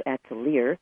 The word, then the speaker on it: Atelier: Actually, I pronounce it Atelier because that's a phonetic pronunciation, A-T-E-L-I-E-R.